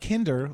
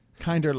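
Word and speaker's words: Kindr: Not Kinder, like Tinder, Kinder, like Grindr.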